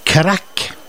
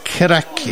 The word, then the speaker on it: craic: Og det uttales crack. – Ja. – Det skrives ikke på den måten. – K-R-A-K? – Ja, det uttales crack. Ja. K-R-A-K-K. – Ok. – Men den skrives på gaelisk C-R-A-I-C.